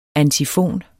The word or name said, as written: antifon